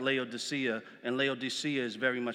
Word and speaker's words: Laodicea: This is this is um, and this is talking to the church of uh, Laodicea, Laodicea, excuse me. Laodicea is the proper pronunciation, but it's speaking to this church.